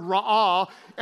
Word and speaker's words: ra'ah: The Hebrew word is the word ra'ah, and you pronounce it this way. It means to see. He saw her, ra'ah. He went, ra'ah. That's how you pronounce it.